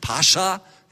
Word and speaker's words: Pascha: Also bitte, wenn Sie es richtig aussprecht, so wie es dem Hebräischen und dann der griechischen Fassung überspricht, Pascha. Können Sie das mal alle nachsagen? Genau, also nicht Pascha, ja.